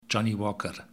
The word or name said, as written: Johnnie Walker